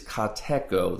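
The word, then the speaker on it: kateko (κατέχω): Now the Greek word for this word that's translated hold fast is kateko. That's the way it's pronounced.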